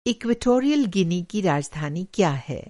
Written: इक्वेटोरियल गिनी

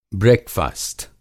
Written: ब्रेकफ़ास्ट

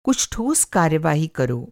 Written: कार्यवाही

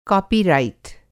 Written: कॉपीरइट

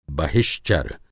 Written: बहिश्चर

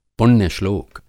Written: पुण्य-श्लोक

